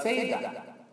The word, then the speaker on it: Saida: Now Sidon is pronounced in Arabic as Saida.